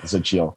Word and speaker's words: Xochitl: It's got the hardest name in the world to pronounce, zochille, which is an Aztec word for flour. – Oh, is it like spelled with a fucking X? – Yes. Yeah. X-O-C-H-I-T-L, zochille.